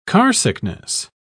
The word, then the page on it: carsickness: British English